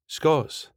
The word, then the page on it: Scots: スコットランド英語